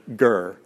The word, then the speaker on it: ger: The Hebrew word for foreigner is the word ger, G-E-R, ger.